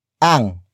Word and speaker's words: ang: The next word is ang. Ang. Ang. Ang is spelled as A and G.